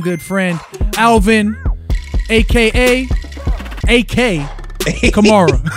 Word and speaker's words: Kamara: You say it like Camaro. Announcers fuck it up continuously. I heard Shannon Sharp. He said, they got Kamara. I said, it's Camara.